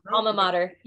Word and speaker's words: alma mater: her Al- alma matter. Is that how you pronounce that? – Yeah, alma mater.